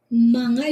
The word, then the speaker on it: mga: Like manga, like M-G? – M-G-A, manga. Manga is pronounced manga.